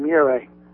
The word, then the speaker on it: Murray: Hey, I have a question about Terry Murray, the coach. – Yeah. – Here's the thing. I've been listening to you for years. – Well, he rhymes with Bure, Murray.